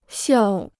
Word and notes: 笑: xiào

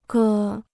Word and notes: 歌: gē

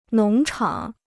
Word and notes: 农场: nóng chǎng

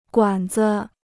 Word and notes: 管子: guǎn zi

